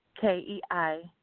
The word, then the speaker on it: Chakeita: And I'm also on Facebook, same, Chakeita Andrews. And it's spelled different from what everybody might think. So it's Chakeita, and last name Andrews, Andrews.